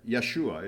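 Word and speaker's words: Yeshua (ישוע): Yeshua is how you pronounce it in Hebrew.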